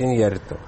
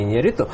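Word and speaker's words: Iñárritu: которое он, безусловно, является фильмом Иньярриту, правильно говорит Иньярриту, а не Иньярриту.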